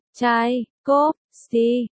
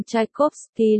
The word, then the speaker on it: Tchaikovsky: Tchaikovsky, 7 tháng 5 năm 1840, 25 tháng tư lịch Julius, 6 tháng 11 năm 1893.